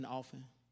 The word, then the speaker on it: often: Did you know you're not supposed to pronounce the T in often?